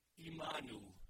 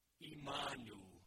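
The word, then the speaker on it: Imanu (עמנו): This is what it sounds like in Hebrew. Imanu. Imanu. Aren't you tempted to add a syllable to that?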